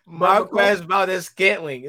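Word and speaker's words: Marquez Valdes-Scantling: Marquez Valdez-Scantling. It's not that hard. Scantling, bro.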